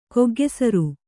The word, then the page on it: ಕೊಗ್ಗೆಸರು: koggesaru